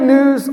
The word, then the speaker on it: gnu: It's good news unlimited. And I don't know, city kids, if you're familiar with a gnu, or pronounced new, it's another word for a wildebeest.